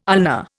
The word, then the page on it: ulna: Indian